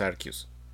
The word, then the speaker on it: Arceus: El nombre, la forma correcta de pronunciarlo es Arceus.